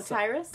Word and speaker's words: Tyrus: And then the moms, which was a funny part to go from that, the moms decide to prank, Tetris? Is that how you pronounce his name? The one we love, Elite. – Oh, Tyrus.